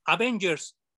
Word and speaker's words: Avengers: se pronuncia Avengers, no Avengers, no hay acento, no es una palabra mexicana ni española ni nada de eso.